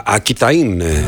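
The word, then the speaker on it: Aquitaine: Ακιταΐν είναι στα γαλλικά, αλλά γράφεται Ακουιτανία που δεν προφέρεται βέβαια, το καταλαβαίνετε, αλλά εμείς επειδή έχουμε μία τάση να προφέρουμε τα πάντα, φαντάζομαι ότι αυτή είναι η περιοχή του Μπορντό.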